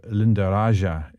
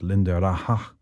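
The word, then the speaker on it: Lindaraja: So Linda Raja isn't right. It's Linda Raja, inspired by Alhambra, because Linda Raja refers to a garden courtyard in that part of Spain.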